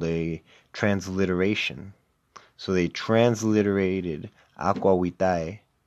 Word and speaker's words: aqua vitae: And the reason why they're calling alcohol water is because in Latin, the phraseology for alcohol, for distilled alcohol, was aqua vitae, or aqua vitae, because you pronounce the V as a W in Latin.